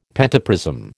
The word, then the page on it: pentaprism: American English